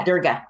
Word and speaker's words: Durga: the image of Durga. I think that's how you pronounce it. Is that right? – Yeah, Durga. Yeah.